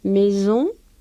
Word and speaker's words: maison: Cuando hay una sola S intervocálica, que lo tienen ustedes con una fonema Z, pues se suena z, z, z, maison, chose, onze, ils ont. ¿Se dan cuenta? Es un sonido más sibilante, digamos, ¿eh?